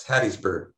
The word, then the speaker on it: Hattiesburg: And then I know that you worked at the Pine Belt Mental Health Care Resources and I don't know if I'm going to pronounce this correctly, Hattiesburg, Mississippi? – It's Hattiesburg.